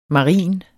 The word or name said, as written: marin